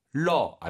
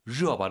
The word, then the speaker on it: 热: 러, 안 돼요. 热. 热 발음 잘하시고요. 삼성삼성 앞에가 이성으로 바뀌죠. 요디알热 잊지 마시고요.